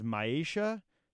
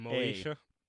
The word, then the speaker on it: Maisha: I think it's pronounced Maisha, M-A-I-S-H-A. – Moisha.